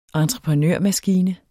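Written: entreprenørmaskine

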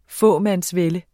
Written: fåmandsvælde

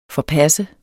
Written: forpasse

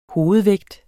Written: hovedvægt

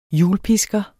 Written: hjulpisker

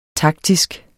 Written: taktisk

